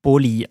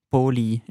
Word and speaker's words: Poli: Sie hat es uns geschrieben mit P-O-L-I, aber sie hat uns noch die Wörter... – Also so wie Polybahn? – Ja, nein, sie hat uns die Wörter, Wörter vorgelesen und dort hat sie es ausgesprochen als Boli. Also Boli. Ein bisschen als Wärmers, als weiches P. Und ich glaube, das L, Boli, ist auch so ein bisschen weich, hat sie glaub, gesagt.